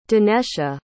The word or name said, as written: danesha